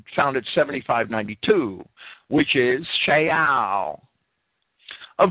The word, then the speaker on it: Sheol: However, in Hebrew, the name Saul can also be spelled the same as the Hebrew word Sheol, the Hebrew word which we in English pronounce Sheol, which refers to the abode of the spirits of the dead, sometimes simplified as a reference to the grave.